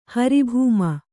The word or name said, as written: ಹರಿಭೂಮ